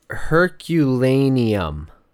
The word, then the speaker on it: Herculaneum: Is it Herculean? I feel like I'm saying that wrong. – The other town? – Yeah. – It's Herculaneum.